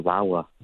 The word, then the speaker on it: Lawa: And I remember the first class, it was I think his name was Dr. Lawa. He said he pronounced it, he was like Bauer without the B, and it's Lawa.